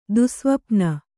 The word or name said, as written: ದುಸ್ಸ್ವಪ್ನ